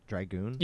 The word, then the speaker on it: Dragoon: Is it Dragoon? – Yep.